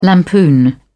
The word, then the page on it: lampoon: British English